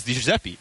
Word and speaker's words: DiGiuseppe: Because he himself says DiGiuseppe, right? But then you said his name in perfect Italian, and we were sitting tableside next to him, and I'm like, whoa, is this how you're really supposed to say your name?